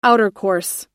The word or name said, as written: outercourse